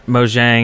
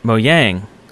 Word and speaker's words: Mojang: He was Swedish, and he told us that it was not Mojang, it was Mojang. That's how you pronounce it.